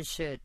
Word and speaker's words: should: Primero practiquemos oraciones con should. – Should se pronuncia con una sh suave y larga, como si se estuviera haciendo callar a alguien.